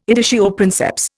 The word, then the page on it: editio princeps: Indian